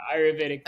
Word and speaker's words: Ayurvedic: And then the other thing I wanted to say, those interesting words that you used, was that a little bit of, I'm probably going to mispronounce it, Ayurvedic medicine? – Yeah, Ayurvedic medicine.